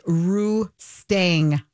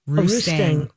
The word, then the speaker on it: Roustang: It's Roustang, like dang. – Roustang.